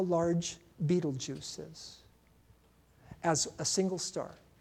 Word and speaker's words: Betelgeuse: It's not like the old movie. It's spelled way different. Betelgeuse. Betelgeuse is how it's pronounced.